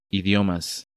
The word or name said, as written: Idiomas